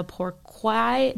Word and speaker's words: pourquoi: There are some that come from Vietnam and the Pourquoi. Did I say that right? – You did, yes. – Yes, it's a French word, so hard one.